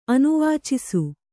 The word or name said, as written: ಅನುವಾಚಿಸು